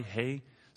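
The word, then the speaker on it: qi: All of the food and substance and day-to-day activities is hei, which is pronounced Q-I, hei.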